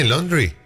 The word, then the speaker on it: laundry: L-A-U-N-D-R-Y. – Laundry. – Exactamente. Laundry. Todo diptongo que en inglés es A-U se pronuncia como O. Es como la marca del auto Audi.